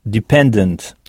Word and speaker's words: dependent: Donc, en début de mot, on entend « di », qui s'écrit pourtant « de », « dependent », avec « ent » à la fin, et non « ant », comme en français.